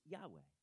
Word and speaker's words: Yahweh: Now, some people pronounce it a little bit differently, but it's Yahweh.